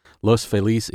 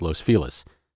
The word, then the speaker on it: Los Feliz: Los Feliz is technically the correct way to say it, but in general usage around L.A., the neighborhood is known as Los Feliz, so that's the version I've gone with here.